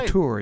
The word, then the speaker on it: auteur: 1935, the director, screenwriter, actor, a true auteur. – Yeah. – Am I saying that right? – Auteur, yeah. – Auteur. – That's correct.